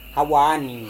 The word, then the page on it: hawa-ni: Serewen